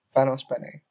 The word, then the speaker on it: Panos Panay: So I was reading this interview, I don't know where it was, Wired or Fortune or whatever, about how Panos Panay, Panos Panay, that's how you pronounce yeah.